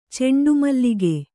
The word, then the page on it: ಚಂಡುಮಲ್ಲಿಗೆ: caṇḍu malige